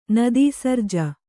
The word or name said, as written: ನದೀಸರ್ಜ